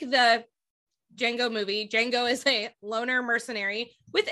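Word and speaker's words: Django: Oh yeah, it's still pronounced the same way, just the D is silent. – That's what I thought. – Okay. – Yeah. – Okay, Jango.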